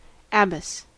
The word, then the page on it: abbess: American English